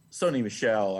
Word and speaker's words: Sony Michel: Like you said, and you said it correctly, for the last 3 weeks, Sony Michel,